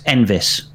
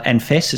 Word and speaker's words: Enfys: It's actually pronounced Envis, not Enfys. It's more like a V.